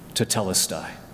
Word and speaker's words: tetelestai: If the word I thirst is two syllables, this is one word with four syllables, tetelestai.